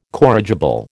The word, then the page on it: corrigible: American English